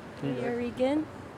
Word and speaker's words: Nuyorican: NUYORICAN.